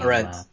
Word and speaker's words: Arendt: Arendt.